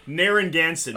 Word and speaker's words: Narragansett: Nigga Nancet. – I'm sorry, come again? – I'm sorry. Nara nigga. Fuck! Fuck! – Narragansett, made on honor, sold on merit.